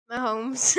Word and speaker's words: Mahomes: So, America, we've got LeBron James, Stephen Curry, Tom Brady, and Patrick Mahomes. And the winner is Tom Brady! – I'm sorry, I have to say this, Killian, but you also pronounced her name wrong. – Is it Mahomes? – Mahomes.